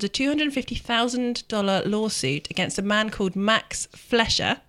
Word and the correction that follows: Fleischer: I may not be pronouncing his name correctly. Flesher? Fleischer? Flesher. – Is there an I? – F-L-E-I-S-C-H-E-R. – Fleischer. Yes, this is American, isn't it? – It's American.